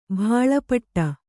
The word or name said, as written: ಭಾಳಪಟ್ಟ